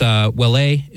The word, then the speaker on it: Ouellet: Cardinal Mark, I believe it's Ouellet is one of the is close enough to the French pronunciation,